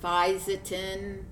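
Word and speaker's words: Fisetin: It's pronounced Physetin.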